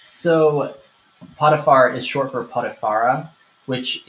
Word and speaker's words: Potiphar: So yes, Joseph has been sold and he now belongs to the master of Pharaoh's guard Potiphar pronounced as in I have diarrhea is the potty far.